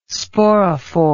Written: sporophore

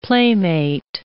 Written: playmate

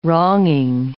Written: wronging

